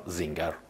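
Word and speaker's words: Singer: سینگر یا اگر بخوایم مدل آلمانی اسمش رو تلفظ کنیم تانیا زینگر آلمانی الاصله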